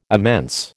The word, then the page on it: immense: American English